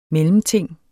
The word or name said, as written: mellemting